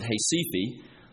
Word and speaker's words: Recife: And was staying in one of the older cities in Brazil, a city called Recife, or as they pronounce it, Recife.